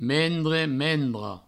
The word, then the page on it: mendre: mendra; mendro